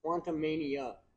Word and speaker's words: Quantumania: If you say that name wrong one more time. – Quantumanium? – Quantumania. – Quantumania, sorry. – There's no M. It's not a UM. It's an A.